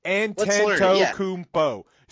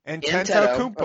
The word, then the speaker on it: Antetokounmpo: So you basically instead of antenna, you put instead of the na, you put kumpo. – Right. – Can you pronounce kumpo? – Anteto. – Antetokounmpo. – Okay. Anteto, just think about that. Anteto. And then kumpo. And remember, the N always comes before the M in his last name.